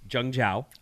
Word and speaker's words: Zhengzhou: so there was this lockdown in China in Zhengzhou. That's the pronunciation. It's spelt with a Z, but it's pronounced Zhengzhou.